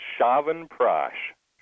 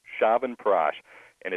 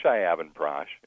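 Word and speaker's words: Chyavanprash: It's called Shavanprash. Shavanprash. And it's spelled Shyavanprash. So it's C-H-Y-A-V-A-N-P-R-A-S-H. So it looks like Shyavanprash. It's pronounced Shavanprash.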